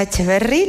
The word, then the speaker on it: Echeverri: Andrea Echeverri, lo he dicho bien, sin acento.